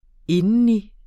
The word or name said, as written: indeni